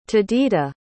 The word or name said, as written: tadita